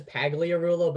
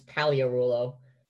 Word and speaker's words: Pagliarulo: Oh, it's a silent G. – It looks like it says Pagliarulo, but it's Pagliarulo. Like just think of the G, and it's perfect.